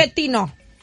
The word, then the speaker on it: Pochettino: Pochettino.